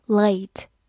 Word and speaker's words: late: late，l a t e，late，字母 a 发它本身的音 a，末尾的 e 不发音。 late，它是个形容词或者副词，表示晚迟。